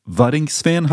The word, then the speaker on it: Waddinxveen: and you grew up in Waddinxveen? How, how, do you pronounce it? Yeah, Waddinxveen.